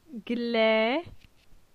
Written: Gle

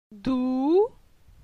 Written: Dou

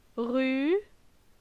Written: Ru

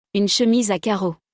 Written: Une chemise a carreaux

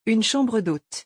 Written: une chambre d'hôte

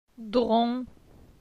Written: Dron

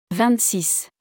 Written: 26

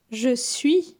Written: Je suis

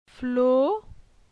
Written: Flo

